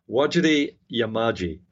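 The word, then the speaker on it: Wajarri Yamaji: I did mispronounce that, actually, because there's when you see it written, what you pronounce is slightly different. It is Wajiri Yamaji. That's the correct pronunciation.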